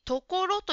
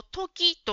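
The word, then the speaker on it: 所: 3 番の質問と答えの文に、ところという漢字が 3 つ出てきますが、ジムさんはこれを時と発音していました。これは時ではなくてところですね。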